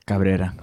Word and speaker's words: Cabrera: Cabrera or something like that? – Yeah, Cabrera. – Oh, I said it right.